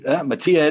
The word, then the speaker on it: Matija: So I figured Matija it is. It gets the J. But yeah, that's how you pronounce it.